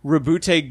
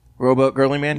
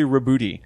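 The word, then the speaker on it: Roboute: They do mention the Custodes pronunciation, but they like, and it's Rebute Gulliman. – Robot Girly Man? – No. – Robot Girly Man. – It is officially Rebute. – Rebute.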